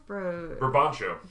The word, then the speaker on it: Brabantio: Brabancio? – Brabancio.